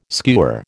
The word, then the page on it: skewer: American English